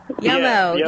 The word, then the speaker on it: Yum-O: Yum-O, yeah.